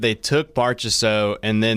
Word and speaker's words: Marchessault: Marchessault.